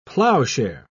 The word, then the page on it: plowshare: British English